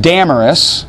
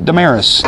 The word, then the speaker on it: Damaris: And also a woman named, and the Greek pronounce it Damaris, although it's my daughter's middle name and we call it Damaris, and a number of others.